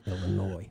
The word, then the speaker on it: Illinois: The S is silent, Illinois.